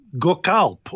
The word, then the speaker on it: Gökalp: The other thing I wanted to mention in this context is that weirdo Gökalp, or however you pronounce his name, right? – No, you got it.